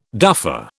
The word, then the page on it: duffer: British English